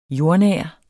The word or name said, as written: jordnær